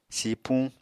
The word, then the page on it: sipuñ: Apatani